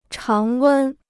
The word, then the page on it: 常温: cháng wēn